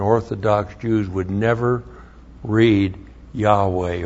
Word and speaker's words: Yahweh: If you pronounce that, it would be Yahweh.